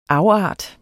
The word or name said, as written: afart